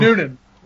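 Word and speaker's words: Newnan: Alan Jackson from Newman, Georgia, got his start working. – Noonan. – What's that? – Noonan. Two N's. Noonan.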